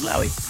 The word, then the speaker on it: Lowry: I did check a audio piece of someone saying his name. – Yeah. – And they did say Lowry or, yeah, Lowry. It was Lowry.